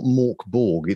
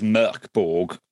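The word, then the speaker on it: Mörk Borg: Because I know it's not Morkborg. It's actually pronounced quite different, isn't it? – Yeah, so I think the official Swedish way is Merkborg, absolutely lots of people pronounce it Morkborg.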